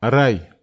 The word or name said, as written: arai